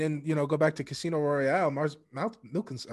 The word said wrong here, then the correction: Mads Mikkelsen: I ruined his name. – Lars! – It's Mads. I know I'm so bad at names. Pronounce it for me. You're better at it. – Mads Mikkelsen.